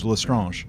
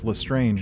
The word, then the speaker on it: Lestrange: I was thinking Bellatrix Lestrange. French. That's how I was pronouncing it in my head, was Lestrange. And then like the movies come out and it's Lestrange, and I'm like, I don't like that.